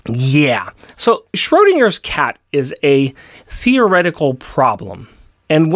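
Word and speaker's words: Schrödinger: Oh wait, can you help me pronounce this? Shrodun Schrodinger's Schrodinger's cat.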